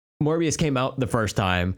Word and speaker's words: Morbius: They are just, anything that Mobius draws is my God, like my God, that man is a fucking, there's nobody like Mobius. There isn't. Like he is Morbius, the living vampire. – Oh my bad. I've been pronouncing it.